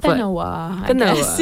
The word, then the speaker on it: Benowa: So I think it's called Benowa. I have no clue how to pronounce it. – Benowa.